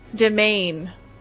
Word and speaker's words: demesnes: Individuals may even cultivate their forest homes into elaborate demesnes. I don't know what that word is. I'd probably pronounce that real wrong. D-E-M-E-S-N-E-S. Demesnes? What is that word? – Domain.